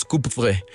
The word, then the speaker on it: Coupvray: ¿Qué recuerdos guardas de tu infancia ahí en Coupvray? ¿Se pronuncia así? – Coupvray, me parece. – Sí, exactamente. Se pronuncia más o menos Coupvray.